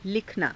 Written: likhanaa

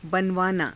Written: banavaanaa

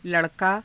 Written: la_rakaa

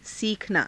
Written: siikhanaa

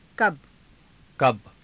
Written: kaba

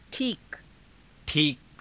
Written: _thiika

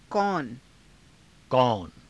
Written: kauna

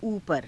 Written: uupara

